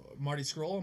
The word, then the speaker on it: Scurll: I think Marty Scurll, am I saying that right? – Yes.